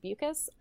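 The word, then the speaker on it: Beukes: By Lauren, I think it's pronounced Bukas. – Bukas, yeah.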